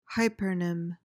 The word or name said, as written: hypernym